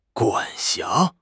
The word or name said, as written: 管辖